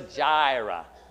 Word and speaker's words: Jireh: Jehovah Jireh. Jireh. Yeah, see, there's a J there.